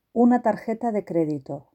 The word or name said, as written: una tarjeta de credito